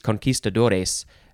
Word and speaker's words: conquistador: You could say conquistador because that's how it's spelled, but that is uh, more an American way to say it because a lot of Americans don't know Spanish. Conquistador, that's how it's pronounced.